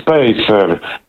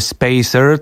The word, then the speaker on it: spacer: No już trzymając się tej angielskiej wymowy spacer, to powiedzielibyśmy, że to jakiś odstępnik albo separator. Ale nam chodzi o spacer w tej wymowie.